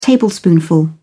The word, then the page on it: tablespoonful: British English